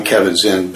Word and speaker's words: Kabat-Zinn: I always said John Kabat-Zinn. – I pronounce it John Kabat-Zinn, but there are many people that use Kabat. The interesting thing is that it's a hyphenated name, you probably noticed, and Z-I-N-N is Howard Zinn.